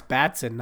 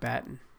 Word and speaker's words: Batson: It's Batson, not Batten.